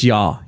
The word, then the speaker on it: Ja: Is it Ja or Ya? – It's Ya. You don't pronounce the J. – Ya. – It's kind of like the G in Jeff.